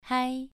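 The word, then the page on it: hai: tone 2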